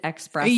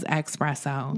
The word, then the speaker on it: espresso: He probably pronounced it expresso. – Yeah. Jake definitely says espresso.